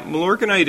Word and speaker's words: Milorganite: Another texter is asking, do you like the product Milorganite? Is that how you say it? Milorganite. Why or why not? – Yeah, Milorganite is just like a lot of other biosolid-based products.